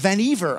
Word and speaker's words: Vannevar: So at the end of World War II, Vannevar Bush, or Vannevar, I found out is how you say it,